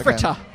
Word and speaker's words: Fritta: It's spelled Fritta, right? – Yes. – Yes. Okay. – Fritta.